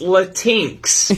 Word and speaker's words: Latinx: It's actually pronounced Latinx.